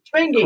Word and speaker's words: Schmengie: Schmangy. – Correct. – Schmangy. – Schmangy. It's spelled S C H M E N G I E. It has a C in it.